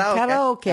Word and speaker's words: karaoke: Come on, let's find some sushi and some karaoke. I said karaoke. Yeah. Because that's how it's actually pronounced.